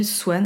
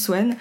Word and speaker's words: Xuan: je m'appelle Swan. Alors c'est très bizarre, ça s'écrit X-U-A-N, mais ça se prononce Swan.